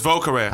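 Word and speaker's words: Vieux Carré: It's Vaux Carrère. – I say car. He says career. Whoever. But we're going to spell it for y'all. – V-I-E-U-X-C-A-R-R-E.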